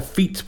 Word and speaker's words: fete: Because it's F-E-T-E. But I've never heard anybody call it a school fete. – Wow. So it's spelt the same, but you just pronounce it different. – And I'm pretty sure I've heard somebody call it a feet before as well. F-E-T-E, feet. – Stinky feet. – Yeah, like F-E-F-E-T, feet.